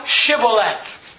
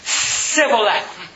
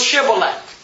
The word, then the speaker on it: Shibboleth: So say now, Shibboleth. And he said, Sibboleth. For he could not frame to pronounce it right. It's like today, somebody, if we're having a civil war. I want to get out of town. Okay, you can get out of town. Say this word. Let me ask my mother. – Let me ask my mother. – That's not how you pronounce it. – Sibboleth. – Nope, it was Shibboleth.